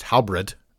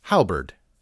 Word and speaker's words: halberd: In the world of pole arms, I have heard the word halberd mispronounced as halberd, as in Hal ate a piece of bread. But it is halberd.